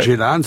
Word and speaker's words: Ceylan: Ceylan, ça s'écrit comme l'île de Ceylan, C-E-Y-L-A-N. – Ah oui, parce que c'est... Voilà, chacun voilà, le prononce comme il veut, mais je pense que vous avez la bonne prononciation parce que vous avez eu l'occasion de rencontrer oui.